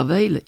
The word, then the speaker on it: Weil: Alors, Pierre Veil, il aurait 100 ans cette année. Donc, au fait, son centenaire. C'est un Alsacien. Il s'écrit W-E-I-L.